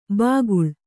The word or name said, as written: ಬಾಕುಳ